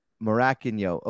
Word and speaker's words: Morakinyo: I'm here today with Tola Morakinyo. Did I say it right? – Yeah. – Morakinyo? – It's like you're a little confused, but yeah. – I know Morakinyo.